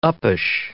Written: uppish

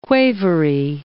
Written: quavery

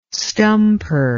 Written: stumper

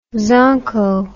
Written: zonko

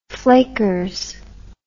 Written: flakers

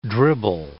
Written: dribble

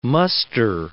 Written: muster

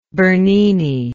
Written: Berninesque

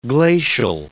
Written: glacial

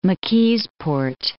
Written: McKeesport

